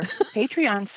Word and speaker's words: Patreon: And if you would like to support this podcast, we would love to have you go to our Patreon site and check it out because we are listening to Patreon.